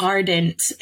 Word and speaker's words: Ardent: So Arden, Jersey? Where's Arden? Where? I've never been there. I don't know where that is. Did I say it right? – If I know, somewhere up north. – Ardent, A-R-D-E-N-T.